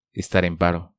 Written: Estar en paro

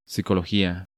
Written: Psicologia

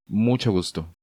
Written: ¡Mucho gusto!

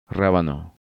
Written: Rabano

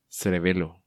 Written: Cerebelo

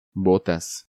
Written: Botas

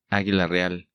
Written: Aguila Real

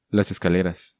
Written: Las Escaleras

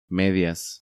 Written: Medias

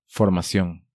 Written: Formacion

